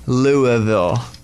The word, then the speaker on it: Louisville: Oh, Louisville, Kentucky. – Ooh. – I know. I'm right, because people probably say Louisville. – No, they don't say Louisville. – What do they say? – It's actually Louisville.